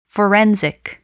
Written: forensic

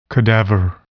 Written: cadaver

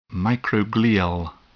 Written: microglial

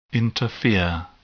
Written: interfere

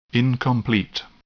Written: incomplete

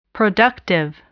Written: productive

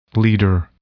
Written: leader

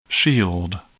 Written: shield

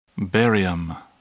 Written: barium

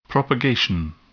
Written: propagation

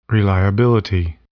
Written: reliability

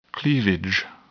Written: cleavage

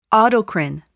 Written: autocrine

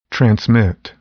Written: transmit

